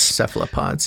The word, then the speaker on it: Cephalopods: Cephalopods. Am I saying that right? – Cephalopods. Cephalopods, yeah.